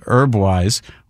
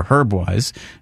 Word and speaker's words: Herbwise: Here's one of the tracks off the new EP that he released called Herbwise, or Herbwise, if you like to pronounce the H.